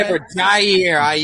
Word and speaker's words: Jair: You said Yair. We're not... – Jair. You didn't hear the J.